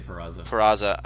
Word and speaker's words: Peraza: Second baseman they got, I think it's Peraza. Is that correct? Did I say that right? – Yeah, Jose Peraza.